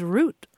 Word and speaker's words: route: Well, this is a great field report, Grant, because this is what sticklers taught for years and years and years, that the pronunciation was root, like the French. – Right. And there's no evidence for that except for personal preference being perpetrated by small-minded pedants, so to speak. And I'm going to say that, just boldly say that, because there is no historical reason that that word could not be either or both of those pronunciations. Root or route, both are acceptable in modern American English, whether it's in the UK or the US, and have been for a very long time.